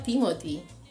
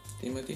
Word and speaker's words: Timothée: Creo que él dijo que se, llama, que su se pronunciaba Timothy en una entrevista, vi eso. – Timothy nomás.